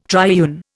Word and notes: triune: Indian

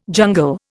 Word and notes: jungle: Indian

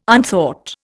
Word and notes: unthought: Indian

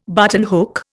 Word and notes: buttonhook: Indian

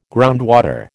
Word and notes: groundwater: American English

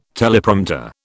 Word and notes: Teleprompter: British English

